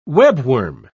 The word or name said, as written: webworm